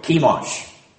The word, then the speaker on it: Chemosh: Chemosh. Hard C-H, like a K.